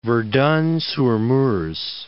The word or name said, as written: Verdun-sur-Meuse